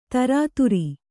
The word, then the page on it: ತಿರಿತರು: Kannada